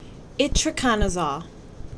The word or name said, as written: Itraconazole